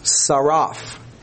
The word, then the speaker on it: tsaraph: In Strong's, this word is T-S-A-R-A-P-H, and it's pronounced saraf, and it is to fuse, as in metals, to refine, figuratively.